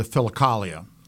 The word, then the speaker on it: Philokalia: Well, I think the starter would certainly be the Philokalia. And I don't know if I've pronounced it right. – Yeah, no, that's about right.